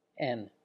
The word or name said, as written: en